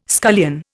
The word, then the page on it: scullion: Indian